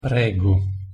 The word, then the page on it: prego: Italian